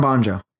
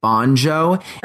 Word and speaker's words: Bonjo: Well, I say Bongo to everyone else, but my last name is Bon Giovanni, so I've always said Bonjo. And because it just means, you know what I'm saying? Like, it just, the pronunciation makes sense.